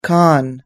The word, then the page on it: con: us-english